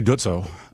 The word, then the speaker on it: Dutzow: I said Dutzow, but what do the locals say? – Well, I say Dutzow. – Okay. – So I think that's the correct pronunciation.